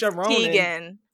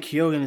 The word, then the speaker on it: Keoghan: It's not Keoghan. It's Keoghan. So, I've been saying Keoghan this entire time.